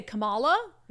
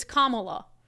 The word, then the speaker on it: Kamala: And she was like, I just want to let you know you're pronouncing Kamala Harris wrong. So apparently I say Kamala, mm-hmm. but it's Kamala.